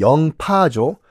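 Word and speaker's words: 링뽀 (寧波): 1543년에 중국 그 명나라 링뽀 그러니까 우리식 발음은 영파죠.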